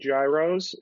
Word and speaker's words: gyros: So we're going to get into a point of contention here about the pronunciation. I'm adamant that they're called gyros, and the campaign was Arby's Greek gyro.